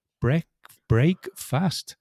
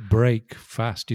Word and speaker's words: breakfast: It's because you don't pronounce it break fast. You say breakfast. So it doesn't sound like, you know, it's an amalgamation of two words.